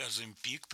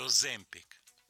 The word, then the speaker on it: Оземпик: Поскольку идет программа наша с тобой, и следующая песня содержит рассказ мой, заготовленный рассказ, где фигурирует слово, вот это медпрепарат, который ты, Оземпик, причем ты правильно поставила ударение. В иностранном варианте Оземпик.